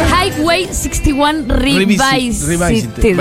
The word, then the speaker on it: Highway 61 Revisited: Highway 61 Revisited.